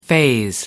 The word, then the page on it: faze: American English